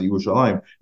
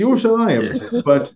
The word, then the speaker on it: Yerushalayim: And they always, because of the orthography, there's no yud, there's no second yud in the word Yerushalayim as it's written, but you still pronounce it Yerushalayim.